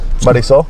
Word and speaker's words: Marisol: Is there a Hispanic way to pronounce that name, or is that really how it's said? – Julio and Jacqueline. – No, Marisol. – Marisol. – Marisol. – Marisol. – Yeah. – Marisol? – Marisol. M-A-R-I-S-O-L.